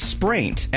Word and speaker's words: spraint: What is spraint? S-P-R-A-I-N-T.